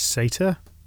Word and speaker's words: satyr: If you're wondering what a satyr is, apparently the American pronunciation is satyr. That is S-A-T-Y-R.